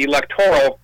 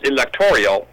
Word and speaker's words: electoral: See, electorial is not a word, and electoral isn't pronounced electorial.